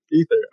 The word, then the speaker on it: Aether: We've got from, is it Ether or Aether? – Ether. – Okay. Now I'm like questioning everything. – Even though I know it's Ether, I still like, you know, pronounce it Aether for some reason. Just because that visual A, that A is just like, yes, so present that I have to pronounce it, you know.